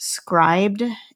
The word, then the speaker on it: Scribd: There's a website called, I think it's pronounced Scribd, it's S-C-R-I-B-D, and that website, you can have a monthly subscription for like $11 or $12 a month.